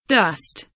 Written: dust

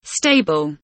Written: stable

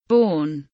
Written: born

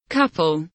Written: couple

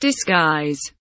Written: disguise

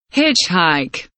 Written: hitchhike

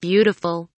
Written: beautiful

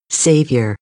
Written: savior

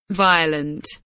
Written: violent

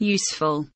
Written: useful

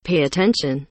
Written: pay attention

